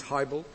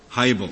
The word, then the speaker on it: Hiibel: Am I pronouncing your client's name correctly? – Your Honor, it's Hiibel. – Hiibel, very well.